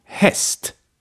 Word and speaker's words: häst: Say after me, häst. This means horse in Swedish.